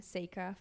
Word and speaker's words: Seyka: So it's a play on my name, Seyka, For Goodness Sake. – And it's spelled S-E-Y-K-A.